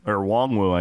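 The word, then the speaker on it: Nwangwu: Hey, Kenny Nwangu, Wongwu, I guess. We mispronounced his Wongwu.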